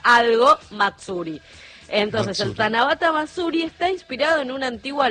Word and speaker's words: Tanabata Matsuri: Tanabata Matsuri.